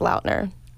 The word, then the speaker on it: Lautner: His last name is actually pronounced Lautner.